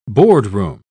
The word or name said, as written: boardroom